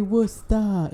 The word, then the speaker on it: Worcester: Hey, Worcester.